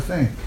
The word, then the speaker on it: Sai: Saya, pronounced approximately like Sai, is a Persian word for saint.